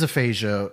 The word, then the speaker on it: aphasia: Bruce Willis's family announced that he is stepping away from acting following aphasia diagnosis. I don't even know. I should have looked up how to pronounce that word. – It is aphasia.